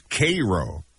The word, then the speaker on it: Cairo: It looks like Cairo, but it's Cairo, Nebraska.